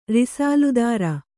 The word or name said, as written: ರಿಸಾಲ್ದಾರ